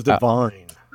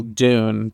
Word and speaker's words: DVNE: D-U-N-E, like the movie? Or D-V-N-E? – I think it's D-V-N-E, but they still pronounce it. They still pronounce it Dune. – I thought it was Divine.